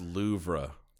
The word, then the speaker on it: Louvre: It's pronounced Louvre.